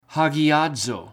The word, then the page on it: hagiaz: Greek